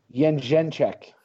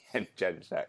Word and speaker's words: Jędrzejczyk: And then the women's fight, Valentina Shevchenko versus Joanna, and I never say this right, Jędrzejczyk or something like that. How do you say it? – Jędrzejczyk. – Jędrzejczyk, yeah.